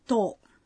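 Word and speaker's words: to (とお): O número 10 é especial, ele não tem tsu no final, e a pronúncia é to.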